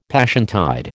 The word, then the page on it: Passiontide: American English